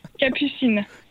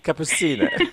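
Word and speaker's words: Capucines: And the address is 26 Boulevard de Cappuccine. – Cappuccine. – Cappuccine. Thank you.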